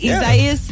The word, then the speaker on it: Isaias: Did I say it right? Isaias? – Yeah. – Is that right? – It doesn't feel right. – I feel very, what's wrong with you? – I feel like I'm saying it wrong. – It's correct. No, Google had it messed up.